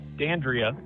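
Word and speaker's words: Dandrea: Dandria.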